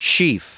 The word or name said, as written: sheaf